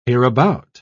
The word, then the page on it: hereabout: British English